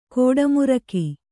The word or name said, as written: ಗೌಡಮಾರ್ಗ